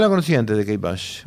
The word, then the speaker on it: Kate Bush: Vamos a pasar ahora a una música de Kate Bush o Kate Bush.